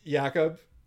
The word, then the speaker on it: Jakub: Anyway, I'm pronouncing it Jakub, based on it being a K. I apologize to Mr. Chychrun if I am mangling yet another name. – I feel like it actually is Jakub Chychrun, but but I've heard people say this, and this is why I'm uncertain now.